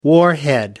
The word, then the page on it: warhead: American English